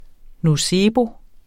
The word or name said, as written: nocebo